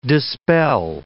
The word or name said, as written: dispel